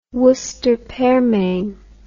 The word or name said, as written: worcester pearmain